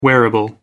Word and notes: wearable: American English

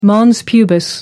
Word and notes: mons pubis: American English

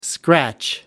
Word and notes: scratch: American English

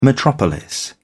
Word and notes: metropolis: British English

